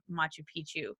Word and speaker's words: Machu Picchu: Machu Picchu.